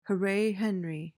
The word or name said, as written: hooray henry